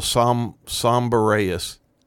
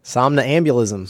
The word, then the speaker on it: somnambulism: Homicidal somberaeus. – Somberaeus? – Homicidal somberito. – Somnambulism. – Somnambulism.